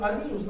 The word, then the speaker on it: Арминий: Кстати, вот Арминиус, надо говорить, это больше его латинское произношение. Вообще можно назвать его Арминий.